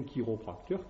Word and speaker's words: chiropracteur: Ce qui explique, voilà, nous arrivons enfin à des mots, hein, par exemple, que dans, on dit chiropracteur, ça s'écrit CH, hein, d'accord, mais on dit bien chiropracteur, chiromancie, c'est la racine de la main, hein, et pourtant, on dit aussi chirurgien.